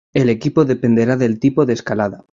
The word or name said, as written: escalada